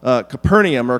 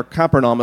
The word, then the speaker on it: Capernaum: So the call of Matthew is powerful on several levels, because this happens in a town called Capernaum, or Capernaum, as some pronounce it, and this is by the sea, kind of the northern section of the Sea of Galilee.